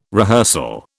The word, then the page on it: rehearsal: British English